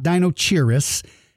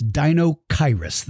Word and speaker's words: Deinocheirus: The CH sounds like the letter K. It's not Dinochirus. It's Deinocheirus. The CH letter makes a sound makes the sound of a K in this particular word. So, Deinocheirus.